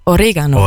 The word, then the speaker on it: Oregano: Ich habe ewig lang Oregano falsch ausgesprochen. Es ist Oregano.